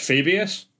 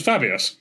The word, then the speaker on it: Fabius: So, Fabius Bile, to me, always sounds like, or always looks like it should be pronounced Fabius. – Yeah. – And it's not. It's pronounced Fabius, which annoys me.